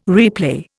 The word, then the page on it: replay: Indian